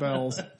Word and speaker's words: Beals: Spelled Beals, but pronounced Bell's.